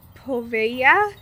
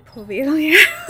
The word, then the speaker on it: Poveglia: But the place that we're talking about today is Poveglia Island, pronounced Poveya. So I don't pronounce it correctly at all. It's spelt Poveglia, but pronounced Poveya. I pronounce it Poveglia because I am not good at language.